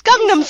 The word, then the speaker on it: Gangnam: It's Gangnam Style. I said it correctly. – Oh, is it? – It's Gangnam.